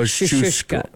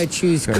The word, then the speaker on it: Kosciuszko: First syllable. – Why is it? – Is cuz. – Oh, cuz. – Like the word cousin. The second one is choose. Like choose or lose. – Kosciuszko.